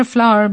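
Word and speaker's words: flour: তো FLOER ফ্লাওয়ার মানে ময়দা, সেই ফ্লাওয়ার আর ফুল ফ্লাওয়ার বানান আলাদা হলেও উচ্চারণটা একই।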